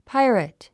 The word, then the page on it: pirate: páiərət; パイレェット